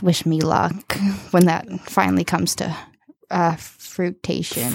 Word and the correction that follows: fruition: Fruition. – Is that really how you pronounce it? – Fruition.